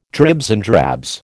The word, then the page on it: dribs and drabs: American English